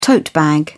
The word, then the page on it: tote bag: British English